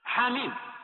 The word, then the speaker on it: حم: وكذلك حاميم وهي محل الشاهد، فإنها تكتب حاء وميم فقط حرفين، ولكنها تنطق بخمسة حروف حاء ميم.